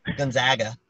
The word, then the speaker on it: Gonzaga: Gonzaga.